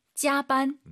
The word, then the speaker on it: 加班: 加班. – 짜반, 쌍비읍보다는 약간 비읍 느낌으로 힘 빼시고 짜반 하면 좋을 것 같아요.